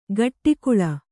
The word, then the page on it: ಗಟ್ಟಿಕುಳ: gaṭṭi kuḷa